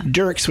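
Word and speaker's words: Dierk's: Dirk's, which is it's funny because it's spelled D-I-E-R-K.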